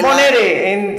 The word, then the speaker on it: Monere: Entonces, Monere. Pero ¿quién mejor para decirnos cómo se pronuncia? ¿Qué Monere? Moner, Moner.